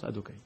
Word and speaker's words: Adokai: quand le yud » il est à côté d'un he, et à côté d'un vav, et à côté d'un va, he, ça se prononce différemment. Ça se prononce adokai.